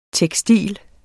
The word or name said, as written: tekstil